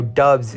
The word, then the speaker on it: Doubs: He's Actually, Romeo Doubs is the name. It's pronounced Doubs. Very easy to confuse that.